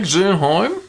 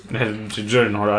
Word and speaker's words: Gyllenhaal: Ja, und da ist äh, Jake Gyllenhaal. Gyllenhaal heißt der. Oh, Gyllenhaal.